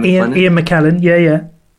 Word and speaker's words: McKellen: I think Ian McLennan, is that how you say his name? – Ian McKellen. – McKellen, yeah, said this in a TV show once.